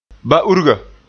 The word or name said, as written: ba-urga